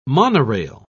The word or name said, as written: monorail